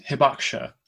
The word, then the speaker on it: hibakusha: So the term is hibakusha, which is a term that means atomic bomb affected person.